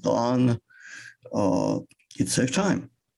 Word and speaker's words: thon: In 1884, a very famous American hymn writer, Charles Converse, coined the pronoun thon, T-H-O-N. And he said, I got it by blending that and one, and it should be pronounced thon.